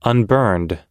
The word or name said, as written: unburned